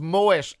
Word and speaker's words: Moët: It's like Moët and Chandon. It's Moët and Chandon. – Stop remember? – It. – Yeah, you pronounce the T.